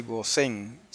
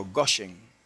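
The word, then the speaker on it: Goshen (גֹּשֶׁן): no dice Gosén, sino Goshen. Tiene el acento en la O, y así el rabino Mordejai lo traduce así también, lo pone así, con acento en la O, que es la forma correcta, pero ya estamos acostumbrados a decir Gosén y no hay problema con eso.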